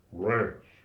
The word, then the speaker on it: Reims: Rance, the proper pronunciation.